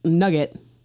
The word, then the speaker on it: nougat: Typically a vanilla block or layer of soft serve sandwiched between one plain wafer and one chocolate-covered nougat one. This is known as a single nougat in Scotland, with a double nougat, pronounced nugget, double nugget. Nougat, nugget.